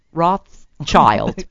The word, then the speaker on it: Rothschild: I have a lisp when I say that. – The S is silent. – Rothschild.